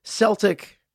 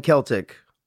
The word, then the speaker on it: Celtic: As a side note, I just looked it up, and both Celtic and Celtic are acceptable pronunciations of that word.